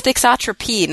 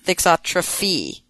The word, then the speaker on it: thixotropy: But it's with a P. It's thixotropy, not thixotrophy. – Okay. – P, Bob. Not PH.